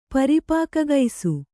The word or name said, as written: ಪರಿಪಾಕಗೈಸು